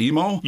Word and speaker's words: IMO: Am I pronouncing it right? Is it IMO? – You are indeed.